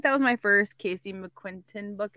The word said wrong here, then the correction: McQuiston: Is that how you say her name? McQu- I think McQuiston. McQuiston yeah. yeah.